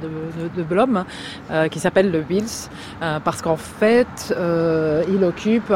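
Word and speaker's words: Wiels: Et donc il y a un arrêt de tram là qui s'appelle Wils, comment tu prononces Wils? On prononce Wils, alors c'est Wils ou Wilmans, sur le...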